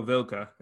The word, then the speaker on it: Vilca: But speaking of cold guys, we've got a new signing, Peruvian starlet, some may say, Rodrigo Vilca. Am I saying that correctly? Maybe. – Yeah, with a little bit of a gringo accent, but it's at least the pronunciation attempt is correct.